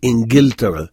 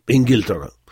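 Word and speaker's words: Ingiltara (إنجلترا): Bon, a priori, on pourrait lire Ingiltara, mais on a toujours prononcé Ingiltara, un peu à l'égyptienne, parce que les Égyptiens prononcent le jim g, donc Ingiltara.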